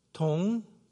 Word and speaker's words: Dong: It's in Korean, it's pronounced, it's two syllables. It's Dong, D-O-N-G, one, like I won.